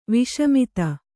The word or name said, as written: ವಸುಮದ